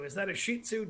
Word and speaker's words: Shih Tzu: You know, when I first met my wife, Debbie, you know how she used to pronounce Shih Tzu? – No. – She used to say a Jiu Tzu.